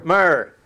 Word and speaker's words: myrrh: M-Y-R. Pronounce that as a word, and what do you get? – Myrrh. – Myrrh.